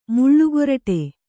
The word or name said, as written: ಮುಳ್ಳುಗೊರಟಿ